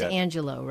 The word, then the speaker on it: Angelou: And it is Angelou, right? – Yes.